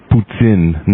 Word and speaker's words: poutine: Also, I would make all the people understand that it's pronounced Poutine, not Poutine, like the Americans say.